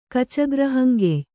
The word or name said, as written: ಕಚಗ್ರಹಂಗೆಯ್